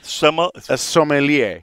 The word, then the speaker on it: sommelier: So what is that word again? – Sommelier. – I can spell it. I can't say it. It's S-O-M-M-E-L-I-E-R.